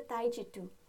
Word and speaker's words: Taijitu: which is actually called the Taijitu.